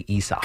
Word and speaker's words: Isaac: I think they move on from him because they're spelled Isaac. So why would you say Isak? – Because that's how you pronounce it.